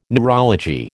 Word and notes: neurology: American English

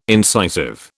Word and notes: incisive: British English

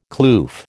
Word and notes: kloof: American English

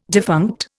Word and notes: defunct: Indian

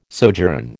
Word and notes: sojourn: American English

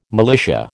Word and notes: militia: American English

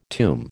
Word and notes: tomb: American English

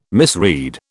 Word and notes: misread: British English

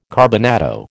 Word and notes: carbonado: American English

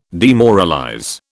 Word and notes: demoralize: British English

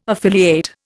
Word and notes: affiliate: Indian

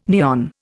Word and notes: neon: Indian

